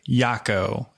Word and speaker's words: Yacko: Okay, guys, today I would like to welcome Victor Yacko, as he says, rhymes with taco,